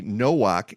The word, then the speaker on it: Nowak: Nowak. He spells it the same way as I do, but he pronounces it with a W, Nowak, and no relation.